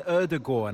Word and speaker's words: Ødegaard: But Martin Ødegaard,